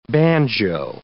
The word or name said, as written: banjo